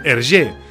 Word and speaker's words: Hergé: El 1922 va començar a signar els seus dibuixos i el 24 va decidir posar-se un sobrenom artístic, Hergé, que era la pronunciació en francès de les seves inicials invertides, R.G.